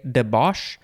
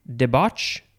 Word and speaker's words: debauch: The first form of debauch, I would want to say debauch with more of an SH, but the pronunciation guide is more of a CH. Debauch or debauch or debauch.